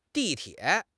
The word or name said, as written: di4 tie3